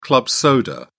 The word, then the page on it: club soda: British English